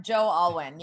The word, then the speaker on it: Joe Alwyn: She's supposedly with this guy, this actor Joe Alwyn. How do you say his name? Do you know? – Um, Joe Alwyn.